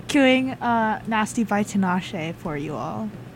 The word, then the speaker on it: Tinashe: What pronounce that? – Tinashe. – Tinashe. – Yes.